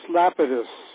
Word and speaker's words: Lapidus: By the way, I'm looking at another book here, In Pursuit of Gold, Alchemy Today in Theory and Practice by Lapidius. And Lapidius, this is the one on metallic alchemy. How do you like that one? – It's pronounced Lapidus. – Lapidus, okay.